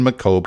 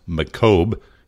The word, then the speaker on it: Macomb: Macomb County, but they are unsubstantiated. Being that I'm from Chicago, I know it's not pronounced Macomb, it's Macomb. I get that.